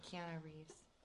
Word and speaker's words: Keanu Reeves: So Neo is played by, I think it's, how do you say, Keanu Reeves? – Yeah, Keanu Reeves.